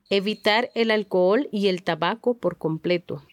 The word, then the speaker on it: alcohol: El alcohol. – Note, the H in alcohol is silent. Repítelo dos veces. – El alcohol. El alcohol.